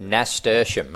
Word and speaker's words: Nasturtium: Nostridium? How do you pronounce it? Nasturtium, mate. Nasturtium, it's named.